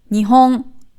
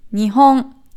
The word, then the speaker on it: Nihon: Es gibt aber auch ein Wort, das die gleiche aus, also die gleichen Silben hat, aber von der Betonung her anders ist. Und das ist Nihon.